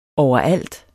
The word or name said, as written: overalt